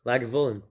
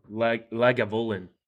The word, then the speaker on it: Lagavulin: Lagavulin. – Lagavulin.